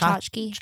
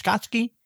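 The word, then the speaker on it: Chachki: How do you pronounce it? Violet's name? – Violet Tchotchke. – Tchotchke. – Tchotchke. – Tchotchke. – Tchotchke. – Like Tchotchke. – Oh, Tchotchke. Tchotchke.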